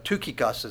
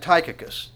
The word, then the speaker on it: Tychicus: And what's also interesting, if you look at it with me in verse 12, but and here's one, another one of these names that if you pronounce it according to the way it looks, it's going to be different. Tychicus is actually the way it's pronounced, but Tychicus would be fine.